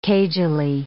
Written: cagily

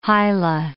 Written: hila

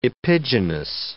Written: epigynous

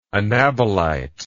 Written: anabolite

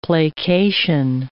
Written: placation

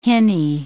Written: hinny

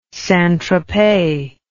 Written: sainttropez

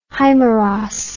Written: himeros

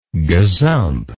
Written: gazumper

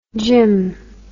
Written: gymn

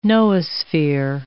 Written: noosphere

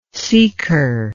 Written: seeker

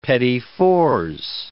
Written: petit fours

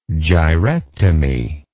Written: gyrectomy

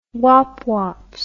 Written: wop wops

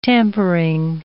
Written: tampering

